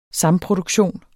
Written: samproduktion

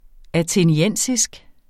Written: atheniensisk

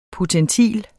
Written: potentil

